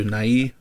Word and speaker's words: Nye: You put emphasis on the Y? – I believe you are supposed to. Nye. – Oh, okay. I always pronounce it Bill Nye, as in the science guy.